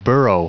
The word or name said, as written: burrow